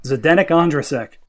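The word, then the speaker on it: Zdeněk Ondrášek: If the 80 million pound Harry Maguire cannot defend the Cobra, Zdenek Ondrasek.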